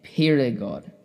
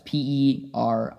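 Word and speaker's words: Périgord: Perigord. Said that wrong, but it's Perigord.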